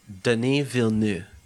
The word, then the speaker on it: Denis Villeneuve: I still don't know how to pronounce the Dune guy we were talking about last week, Villeneuve? – Denis Villeneuve. – Villeneuve. – Villeneuve. – Villeneuve. See? I've said it four different times Denis in the Villeneuve.